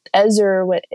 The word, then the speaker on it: Ezer: So the Hebrew word, and I might butcher it, it's Ezer, E-Z-E-R, Ezer, and it means to, in this context, depending on how it's pronounced, to rescue or save, or to be strong.